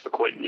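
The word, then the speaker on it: fucoidan: He's telling you about his research with brown seaweed, and in particular a component of it called Fecoidin. Am I saying that right? – Yes, Fecoidin, yep.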